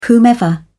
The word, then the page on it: whomever: British English